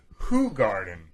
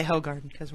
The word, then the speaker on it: Hoegaarden: If you want to pronounce it correctly, it's Hoegaarden. – Hoegaarden. – But we just say Hoegaarden.